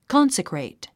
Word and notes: consecrate: kɑ’nsəkrèit; コンセクレイト

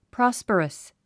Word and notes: prosperous: prάspərəs; プロォスパレス

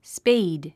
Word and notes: spade: spéid; スペェイド

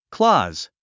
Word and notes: clause: klɔ́ːz; クローズ

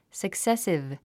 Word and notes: successive: səksésiv; サクセスィブ

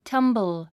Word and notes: tumble: tʌ́mbl; タンボゥ

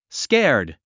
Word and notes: scared: skéə r d; スケァド

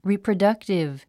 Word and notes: reproductive: rìprədʌ́ktiv; リィプロダクティブ

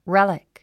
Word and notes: relic: rélik; レェリック

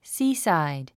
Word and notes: seaside: síːsὰɪd; スィーサァイド

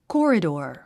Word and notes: corridor: kɔ́ːridər; コォリドオ

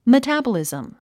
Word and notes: metabolism: mətǽbəlìzm; メタァバリズム

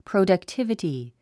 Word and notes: productivity: pròudʌktívəti; プロダクティブティ

